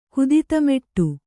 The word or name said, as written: ಕುದಿತಮೆಟ್ಟು